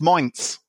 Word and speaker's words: Mainz: And obviously, yesterday we heard that Fernandes has gone to Minsk in Germany. – Minsk. No, not Sk. There's no Sk. It's got a Z on the end. It's just Mainz.